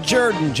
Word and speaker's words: Jordan: This is Shug Jaden, football coach of the Auburn Tigers. It's pronounced Jordan.